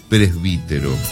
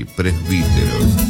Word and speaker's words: presbítero: Eso quiere decir presbítero, con B larga y acento en la I, presbítero.